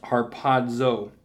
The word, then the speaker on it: harpazo: The Greek word in the New Testament Greek lexicon is harpazo, literally pronounced harpazo, which is defined as to catch away, to catch up, to pluck, to pull, to take by force.